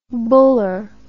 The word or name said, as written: buller